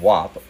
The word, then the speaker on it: Whop: Anyways, this is an article from Fast Company, and the title of it is Inside WAP, the three, I guess it's called WAP, it's spelled W-H-O-P, Inside WAP, the $300 million Gen Z marketplace for selling your expertise.